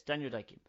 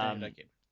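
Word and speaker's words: Daniel Dae Kim: Yes, Daniel Day-Kim. – Yeah, Daniel Day-Kim.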